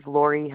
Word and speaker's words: Laurie: Yep, my zip code is 59102, and my name is Lori Heimbichner. Do you need me to spell that? – Yes, ma'am. – Okay, Lori is L-A-U-R-I-E. Last name is Heimbichner, H-E-I-M-B-I-C-H-N-E-R.